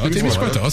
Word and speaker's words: Témiscouata: Tu dis Témiscouata, c'est ça? – Témiscouata, c'est bon. Exact.